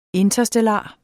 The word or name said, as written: interstellar